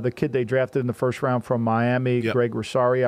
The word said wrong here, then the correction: Rousseau: I think his name, I always mispronounce his name. – Gregory Rousseau. – Rousseau, you know, thank you, fam.